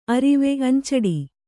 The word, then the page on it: ಅರುವೆ: aruve